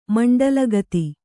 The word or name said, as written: ಮಂಡಲಗತಿ